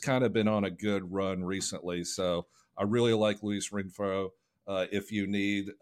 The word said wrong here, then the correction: Rengifo: You're talking about Luis Rengifo, right? Is that what you're saying? – Rengifo, okay. Yes. I do not pronounce the G.